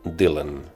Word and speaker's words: Dylan: però ell també va canviar la pronunciació i va dir no, Dylan no, Bob Dylan.